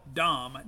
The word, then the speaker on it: Dohm: Now, it's spelled Dohm, D-O-H-M, but it's pronounced Dohm, as in, you know, the Minneapolis Metrodome.